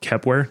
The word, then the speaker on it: Kepware: I think I've heard of something called Kepware. Did I pronounce that right? – Well, yeah,